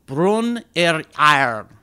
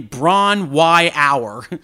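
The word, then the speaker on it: Bron-Yr-Aur: now I'm going to do the Welsh here, Brun yr Iron. Or for those of you who pronounce it differently, Bron Y Hour.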